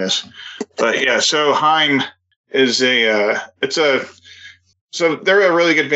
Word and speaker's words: Haim: No, it's Haim. Rhymes with time. – You said Haim.